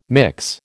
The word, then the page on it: mix: American English